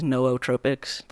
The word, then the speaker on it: nootropics: like apparently the pr- correct pronunciation is nootropics. Like you gotta like it's hmm. pr- pronounce both O's, and that just you nobody, take your nobody does that.